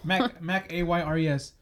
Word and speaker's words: Mac Ayres: Mac A-Y-R-E-S. – Mac A-Y-R-E-S. – Yeah. Yeah, he's like, yeah, this fool, dude. – I would pronounce it maybe like Mac Ayres.